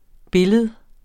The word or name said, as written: billede